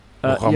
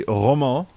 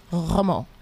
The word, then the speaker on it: Romain: Yeah, it's meant to be Romain. – Romain. – But he says Ramon because South Africans can't pronounce it. So I just swap the A and the O. So say Roman, but then swap the A and the O. So go Ramon. – So it's spelled Roman, but it's pronounced Romain.